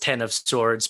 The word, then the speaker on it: X of Swords: it's pronounced Ten of Swords, but it has an X there, so it's sometimes the X is red, sometimes it's a 10.